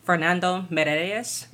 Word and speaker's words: Fernando Meirelles: It's from director, and I'm going to pronounce this terribly, Fernando Meirelles.